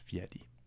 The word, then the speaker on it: Fieri: It's pronounced Fieri.